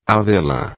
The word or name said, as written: avela